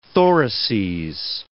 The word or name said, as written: thoraces